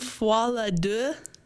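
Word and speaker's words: Folie à Deux: Foie la deux? I can't speak French. How do you pronounce that? – That was really good.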